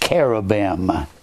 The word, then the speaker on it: cherubim: Actually, the word is pronounced cherubim.